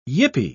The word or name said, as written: yippee